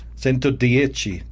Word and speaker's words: Centodieci: The Centodieci, am I saying it right? – Centodieci. – Like Arrivederci.